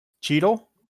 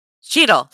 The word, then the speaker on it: Cheadle: Is it Shadell? Is it Rhodey? The guy that plays Rhodey. – Cheadle? – Cheadle, thank you.